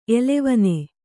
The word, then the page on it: ಎಲೆವನಿ: Kannada